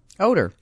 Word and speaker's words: Odor: It's Rouned, R-O-U-G-N-E-D, Rouned. I don't know what that means. But his last name is spelled, it's not pronounced this way, but it's spelled O-D-O-R. – Odor. – It's pronounced Odor.